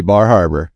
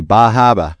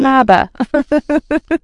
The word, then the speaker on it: Bar Harbor: If you're in Maine, they're not going to know what you're talking about if you say Bar Harbor. – Really? – You got to say Bahaba. – Bahaba. I love that. – That's how they say it. Bahaba.